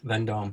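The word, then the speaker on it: Vendôme: vendome is that how you say it yes vendome mm-hmm.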